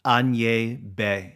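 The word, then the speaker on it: Agnès b.: And I asked Matt how to say it in French um, with a French accent, and he said Agnès Bay Bay.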